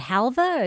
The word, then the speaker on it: halva: And this time, you want to talk about, and I'm not sure, is it halva or is it halva? – That's, we pronounced it halva.